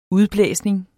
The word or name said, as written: udblæsning